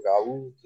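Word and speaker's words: Gauld: O meu, o meu caro Tomás está aqui nos bastidores uh, a relembrar, mas um grande jogo do Ryan Gold, ou Gaúd, como preferirem chamá-lo. E, adorei, e... Adorei, adorei, a tua pronúncia escocesa.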